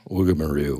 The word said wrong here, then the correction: Woomera: What is it called? Oh, Woomera.